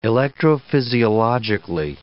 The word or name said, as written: electrophysiologically